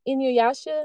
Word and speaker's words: Inuyasha: I told my friends about this cool cartoon called Inuyasha, as I would pronounce it back then. and she was like, You mean Inuyasha?